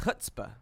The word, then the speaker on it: chutzpah: I guess it's pronounced chutzpah. The C is silent.